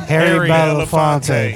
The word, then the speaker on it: Harry Belafonte: How do you say his name? – Harry Belafonte. – Harry Belafonte.